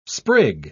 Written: sprig

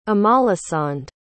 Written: amalasand

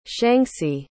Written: shanxi

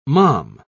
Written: mom

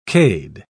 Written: cade

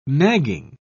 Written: nagging

